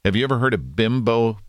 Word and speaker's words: Bimbo: I don't think they understand how that translates up here. – Yeah, right. Exactly. – It's a very derogatory word. It's spelled B-I-M-B-O. It's bimbo in America.